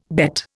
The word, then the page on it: bet: Indian